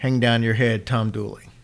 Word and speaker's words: Dula: A man named Tom Dula, pronounced Dooley, but it's D-U-L-A is how it's mm-hmm. spelled, but it's pronounced Dooley, was convicted and hanged for the crime.